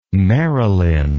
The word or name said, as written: Merrilyn